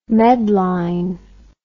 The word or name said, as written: medline